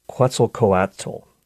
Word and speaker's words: Quetzalcoatl: Don't ask me to spell it, but it's pronounced Quetzalcoatl.